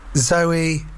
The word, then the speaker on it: Zowie: This is true even if you get confused by the name of his son Zowie Bowie, now Duncan Jones, because in that case Zowie is pronounced like the girl's name Zoe, and not the more expressive Zowie. So it's Zoe Bowie.